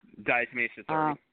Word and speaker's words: diatomaceous earth: Diatomaceous earth.